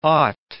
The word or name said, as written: ought